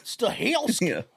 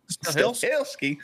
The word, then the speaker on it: Stahelski: I almost said that wrong. – Stahelski. – Yeah. Stahelski.